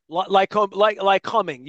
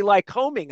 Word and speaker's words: Lycoming: tell people that, and people do, but I tell them if you take the LY off, the word COMING is coming. – Right. – So it's just like coming. But Lycoming.